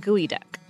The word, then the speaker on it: geoduck: Yes, the word is spelled in English G-E-O-D-U-C-K, so it looks like geoduck, yes. – Which is not what it's called. – Nope. – No. – Nope. Not at all.